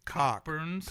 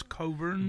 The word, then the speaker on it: Cockburn's: And it's spelled Cockburn's. – Cockburn. – But it's Coburn's.